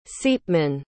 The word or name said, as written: ceapmann